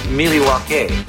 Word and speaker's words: Milwaukee: Oh, are you in Milwaukee? Oh, I'm sorry. – North of Milwaukee. – Actually, it's pronounced Miliwaukee, which is Algonquin for the good land.